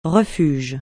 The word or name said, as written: refuge